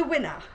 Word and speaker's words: Wiener: The one that puzzled me was the, now, did you pronounce this, the third one, Wiener, the sausage? Wiener, thank you, the sausage. So when they came up to do their presentation, they said, we're group three, pronounce the winner.